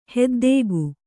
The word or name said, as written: ಹೊದ್ದಿಕೆ